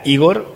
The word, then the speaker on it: Igor: Tuvo el problema de que el cura no quería bautizarme con ese nombre porque ella quería, bien vasca ella, quería que fuera Igor con acento en la I, que es el nombre comunista.